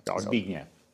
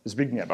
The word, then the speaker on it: Zbigniew: Zbigniew. – Zbigniew, okay, there you go, fine.